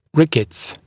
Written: rickets

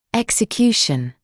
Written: execution

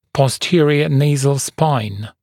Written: posterior nasal spine (PNS)

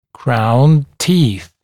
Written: crowned teeth

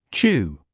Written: chew

